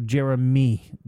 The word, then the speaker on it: Jeremih: Jerib me? – No, Jeremy. J-E-R-E-M-I-H.